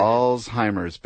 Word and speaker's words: Alzheimer's: I like to say, remember all's fair. – All's so, fair. Alzheimer's,